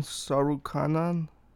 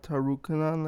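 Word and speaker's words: Tsarukyan: And the Armenian guy, the T is silent, so it's Armen Sarukhanan, not Tarukhanan like I thought it was.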